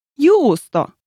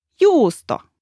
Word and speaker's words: juusto: Now, here's a letter that can be slightly tricky, the letter J. It is a little bit like the Y in the word yes. – Yes, that's how it's pronounced. Give us an example, please. – The word juusto, which means cheese, actually starts with the J.